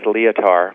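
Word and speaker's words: Lietaer: But particularly a book by Bernard Leitauer. Is that how you pronounce his name? Or Leitauer? – I know it as Leitauer. Bernard Leitauer.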